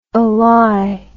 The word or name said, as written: alliable